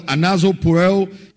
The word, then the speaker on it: anazopureo: Anazopureo is A-N-A-Z-O-P-U-R-E-O. I'll spell it again. A-N-A-Z-O-P-U-R-E-O.